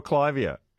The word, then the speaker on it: Clivia: What is it with, is it pronounced, is it Clivia or Clivia? – I call it a clivia.